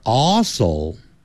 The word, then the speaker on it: also: We are also, also, 你看 ,AL 有一个 L, 所以 all, 舌尖抵上去 ,also, 你不能懒惰 ,also, 如果你念成 also, also, 这舌尖没有抵上去就不对 ,also, we are also good friends.